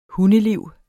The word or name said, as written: hundeliv